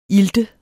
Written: ilt

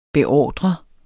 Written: beordrende

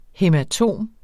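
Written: HÆMATOM

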